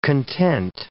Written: content

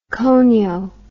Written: cono

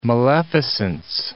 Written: maleficence

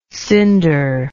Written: cinderlike

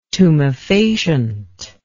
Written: tumefacient

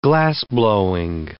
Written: glassblowing

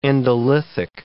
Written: endolithic

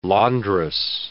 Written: laundress

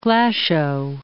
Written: Glashow

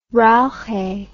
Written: reiche

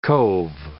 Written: cove

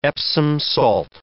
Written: Epsom salt

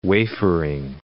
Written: wafering

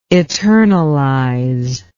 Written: eternalization

